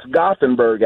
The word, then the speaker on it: Gothenburg: I think I might have mispronounced Gothenburg. Hey. – Hey, how we doing? – Great. – Yeah, it's Gothenburg, actually.